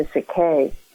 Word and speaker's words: Sikay: Phonetically, it is pronounced as Siki. So I came up with a spelling of S I K A Y, also because I liked the word K.